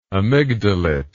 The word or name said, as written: amygdalate